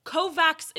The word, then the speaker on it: Kovacs: and also Kovacs is how it's pronounced in some regions, so like, fuck off.